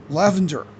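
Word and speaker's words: Lavender (LoVendoЯ): And it's pronounced Lavender. But it's spelled Lavender. – L O. – Like the British Lavender or something, I don't know. – Yeah. It starts with L O and then it's Vendor.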